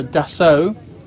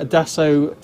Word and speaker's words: Dassault: This is a, Carlos, pay attention, this is a Dassault, not Dassault. or any other pronunciation, a Dassault Falcon 8X, which is one of the French manufacturer's latest models.